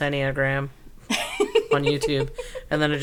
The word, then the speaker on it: Enneagram: I'm probably saying it wrong, but Enneagram, E-N-N-E-A-G-R-A-M, Enneagram, and I think it's Enneagram or Enneagram.